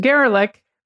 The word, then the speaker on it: Gerlich: And representatives of Mr. Gerlich, which rhymes with derelict, and others denied doing anything improper.